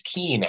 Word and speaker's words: Kuehn: It's pronounced Keen, actually.